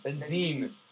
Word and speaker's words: Benzin: Am I pronouncing his name properly? – Craig Benzin. – Benzin.